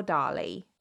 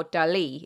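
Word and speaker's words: Dalí: It was in fact created by none other than Salvador Dali. Or Dali, if we're pronouncing it properly.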